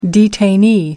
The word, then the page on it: detainee: American English